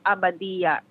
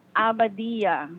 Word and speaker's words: Abadilla: No, Lenny, L-E-N-Y. My last name is Abadilla. A as in apple, B as in boy, A as in apple, D-I-L-L-A. – Labia. Am I saying that correctly? – No, Lenny Abadilla.